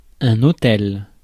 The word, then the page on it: autel: France; [ɔ.tɛl]